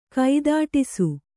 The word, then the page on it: ಕೈದೊಡಿಸು: kaidoḍisu